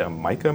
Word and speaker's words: Maika: I think her name is pronounced Micah Monroe, M-A-I-K-A Monroe.